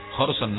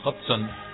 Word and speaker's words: Hodgson: Hodgson no, Hodgson.